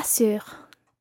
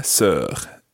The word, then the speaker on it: sœur: Et trois sœurs. – Et trois sœurs. Sœur. Just watch the vowel sound there.